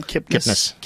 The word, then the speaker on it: Kipnis: How do you say her name? Kipnis.